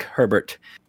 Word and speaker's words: Herbert: Also written by David Lynch, based, of course, on the classic novel by Frank Herbert. Or Hebert, as we would say in Louisiana, although that's not really how he pronounces it.